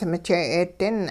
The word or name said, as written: mije'eedin